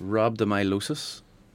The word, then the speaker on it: rhabdomyolysis: Uh, it's pronounced rhabdomyolysis. Exactly. Yeah.